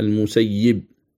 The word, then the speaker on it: المسيب: المسيب.